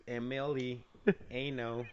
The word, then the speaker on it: Emiliano: That's pronounced Emiliano. – Zapata. – Zapata. Z-A-P-A-T-A.